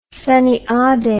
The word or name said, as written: Sunnyade